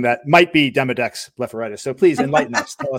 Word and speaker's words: Demodex blepharitis: I'm going to have you talk a little bit about, and I hope I'm pronouncing this correctly, Demodex blepharitis? – Yes.